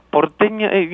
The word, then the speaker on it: Porteña: In fact, your album, did I say the name of that album right? – Porteña, you know, it's yeah, you said it right. Pretty good. – I said it Anglo. – Porteña, if you say it in Spanish, it's Porteña Soledad.